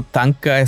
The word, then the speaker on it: thangka: Para por si acaso, Tanka se escribe T-H-A-N-G-K-A.